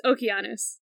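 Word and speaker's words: Okeanos (Ὠκεανός): Okay, I've heard so many ways to pronounce this name. Oh. So you say Oceanus. I've heard Oceanus. – Yeah. – But if you want to say it in Greek, it's Okeanos.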